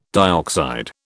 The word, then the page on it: dioxide: British English